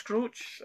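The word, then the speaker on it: Skroce: I think it's Scroce. I get it's a name that I've always struggled to pronounce because I'm not...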